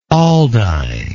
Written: aldine

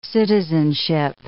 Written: citizenship

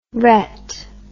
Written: rette

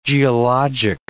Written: geologic

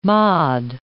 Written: mod

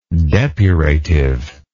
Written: depurative

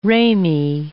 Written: ramie

